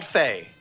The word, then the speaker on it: Fe: It is called Fae. So, I've heard Fae. The developers talk about it. They call it Fae. So, it's spelled incorrectly. We can confirm this. It should be like FEY or something, but they.